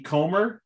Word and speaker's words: Comer: All signs seem to be pointing towards Jodie Comer.